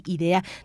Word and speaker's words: Idea: su nombre se dice así, Idea.